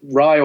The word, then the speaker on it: Rai: So Rye or Ray is exactly how you want to say it.